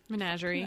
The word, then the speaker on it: Menagerie: And it's in his collection, The Paper Menagerie. Mm-hmm. – Menagerie. – I don't know. – You've got it.